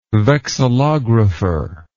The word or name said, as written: vexillographer